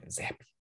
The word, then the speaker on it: Zappe: How do you say his name? Zappy? Zappe? – Yeah, Zappy.